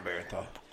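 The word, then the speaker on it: Bernthal: How do you pronounce the last name? Barenthal? Is that it? – Yeah, John Barenthal.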